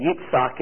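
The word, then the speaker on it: Yitzhak (יצחק): And yet Abraham at 100 and Sarah at 90, she gave birth to the one, again, Yitzhak is his Hebrew pronunciation.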